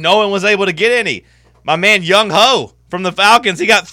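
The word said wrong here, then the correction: Younghoe: Young Wei. – Oh. Am I pronouncing it wrong? – Yeah, it's pronounced Young Wei.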